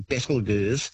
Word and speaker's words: Betelgeuse: It's a fun way of pronouncing the name, actually, Betelgeuse. But the correct pronunciation is said to be Betelgeuse, which comes from an old Arabic name, meaning the armpit of the giant.